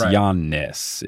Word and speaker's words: Giannis: I would have done Y-O-N-N-I-S. – Interesting. Okay. – Yeah, because I think you hold the yan enough. Like, it's Giannis. It's the two parts of the syllables, like Giannis.